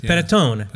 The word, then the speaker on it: panettone: But it's so softly pronounced by panettone. An actual Italian. It's panettone.